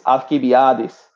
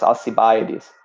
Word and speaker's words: Alcibiades: Another is Alcibiades, or some in English pronounce him as Alcibiades.